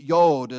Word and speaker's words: yod: The yod is how I was taught how to pronounce it in the Hebrew alphabet.